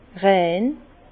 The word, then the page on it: rein: Bas Rhin; Herrlisheim